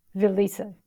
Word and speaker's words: Valize: Valize.